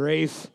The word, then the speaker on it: Ralph: It's spelled R-A-L-P-H. That's Ralph. – Yeah. Rafe.